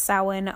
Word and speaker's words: Samhain: Or Samhain, which when you see it written out, it looks like it says Samhain, but it's actually pronounced Samhain, and that is the Halloween for Wiccans, as you will.